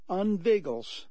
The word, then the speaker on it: inveigles: It's pronounced unveigles. That is I-N-V-E-I-G-L-E-S. Unveigles.